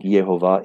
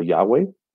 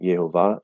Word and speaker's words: Yehovah: So I think Yehovah is the actual pronunciation. I used to make a case for Yahweh or Yahweh, but I think Yehovah is the correct pronunciation.